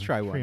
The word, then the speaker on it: Tryone: It's T-R-Y-O-N-E. Try one. – T-R-Y-O-N-E.